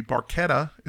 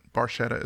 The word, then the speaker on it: Barchetta: It should be Barchetta is the correct pronunciation, okay. – but Barchetta is how uh, Geddy pronounces it.